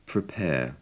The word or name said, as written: prepare